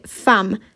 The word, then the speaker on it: femme: I tak, dobrze słyszycie, ja mówię femme. Piszemy przez e, natomiast wymawiamy jako A, to jest wyjątek.